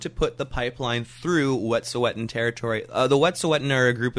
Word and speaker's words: Wet'suwet'en: It's called the Wet'suwet'en. – Wet'suwet'en, yes.